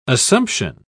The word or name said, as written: assumption